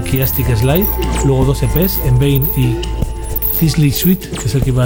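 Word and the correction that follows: Cichli Suite: a decir antes, que me he equivocado, pronunciado, por cierto, Sickly Suite. Ojalá hubiera leído eso antes.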